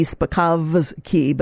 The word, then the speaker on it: Spakovsky: But anyway, Hans von Spakovsky, it's spelled with a V, Spakovsky, but it's silent.